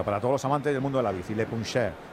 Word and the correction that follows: Le Puncheur: ¿Cómo se escribe Le Puncheur. – Le Puncheur. – Eso es.